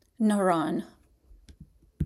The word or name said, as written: Naran